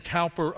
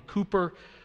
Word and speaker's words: Cowper: There's a fountain filled with blood from Emmanuel's vein, written by, we pronounce his name Cowper a lot, I think it's probably more Cooper, but a fellow by the name of William Cooper wrote a lot of the hymns that we sing.